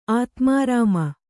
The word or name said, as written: ಆತ್ಮಾರಾಮ